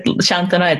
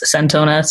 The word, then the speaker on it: Santona: That's without an H, although you pronounce it Santona. It's Santona, spelled Santona.